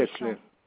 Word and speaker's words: Hincelin: Comment ça s'écrit, s'il vous plaît? – Comment? H-I-N-C-E-L-I-N. Comme ça se prononce.